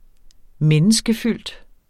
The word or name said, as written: menneskefyldt